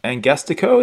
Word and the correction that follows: Angastaco: is it, or I can't remember what it was. – Angastaco. – I'm not going to get the pronunciation right, am I? Yeah, that's right.